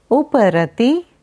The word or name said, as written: Uparati